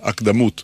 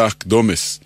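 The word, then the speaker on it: אקדמות: אמרנו שהפיוט נקרא הקדמות, על שם מילת הפתיחה שלו, אבל כבר שמעתי מבטאים זאת הקדמות, כביכול צורת ריבוי. חלק מהטעות קשור לשיטת היידיש, שהרי היידיש הדגישה לא את העברה האחרונה, אלא את שלפני האחרונה. לא הקדמות, אלא הקדומס. מנסיבות שההברה האחרונה לא הודגישה, התעמם הצליל המדויק שהיה בה. במקום צרות אמרו צורס, במקום בהמות בהמס, ולהבדיל במקום הקדמות אמרו הקדומס. התעמם הצליל השונה שהיה בהברה האחרונה. באנו להשיר היום את ההגייה על מכונה.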